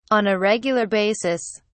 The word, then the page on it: on a regular basis: British English